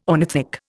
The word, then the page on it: ornithic: Indian